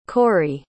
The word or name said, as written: corry